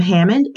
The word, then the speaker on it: Hammond: My name is spelled K-E-L-I, last name Hammond, H-A-M-M-O-N-D.